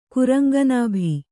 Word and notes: ಕುರಂಗನಾಭಿ: kuraŋga nābhi

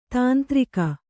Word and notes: ತಂತ್ರಿಕೆ: kannada

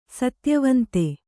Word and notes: ಸತ್ಯವಂತ: satyavanta

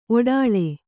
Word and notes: ಒಡಲು: kannada

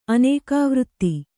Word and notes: ಆನೆಗವರ್ತೆ: ānegavarte

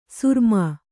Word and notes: ಸುರಮಾ: suramā

